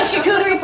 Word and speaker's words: charcuterie: The charcuterie platter. It's very popular. – Is that how you say it? – Yep.